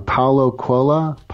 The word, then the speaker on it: Paulo Coelho: Well, John Lennon gets quoted, but it's actually, I always say this guy, his name wrong. Paulo Coelho. Coelho, yes.